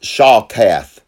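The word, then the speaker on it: shachath: This word destroy is a Hebrew word that is pronounced shakath.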